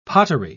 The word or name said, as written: pottery